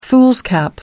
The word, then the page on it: foolscap: American English